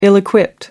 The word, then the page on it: ill-equipped: American English